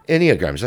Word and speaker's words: Enneagram: Oh, do you mean Enneagram? – Enneagrams, that's it, yeah. – Enneagram, yeah. – I'm saying it wrong, yeah.